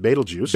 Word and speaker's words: Betelgeuse: And that's how you pronounce it, Betelgeuse.